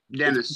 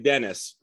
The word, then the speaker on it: Dennys: Dennys. – Pronounced Dennys. Yeah. D-E-N-N-Y-S, but it's pronounced Dennys.